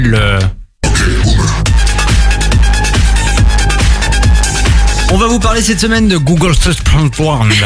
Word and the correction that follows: Google Search Playground: Le Google Search Playground.